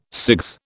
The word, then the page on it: CIGS: British English